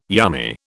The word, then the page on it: yummy: British English